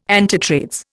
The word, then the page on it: antitrades: Indian